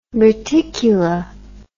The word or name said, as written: reticula